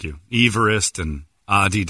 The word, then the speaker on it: Adidas: Americans pronounce Adidas wrong. It's Adidas.